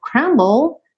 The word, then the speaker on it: crumble: Esse crumble, essas palavrinhas terminadas em BLE, DLE, lembra que esse F final não é pronunciado. Então, ó, bowl. Eu falo o B, vou pro L, L em inglês, língua no céu da boca, e aí termina meu som.